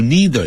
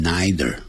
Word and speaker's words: neither: 记住 neither 也可以念成 neither。 Neither dogs nor humans can do，两个念法都可以。